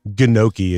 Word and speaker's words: gnocchi: A chicken gnocchi. You want some? – I do. – Chicken gnocchi? – Gnocchi. Gnocchi is what it's how it's spelled, but it's... – G-N-O-C-C-I. – It's like gnocchi.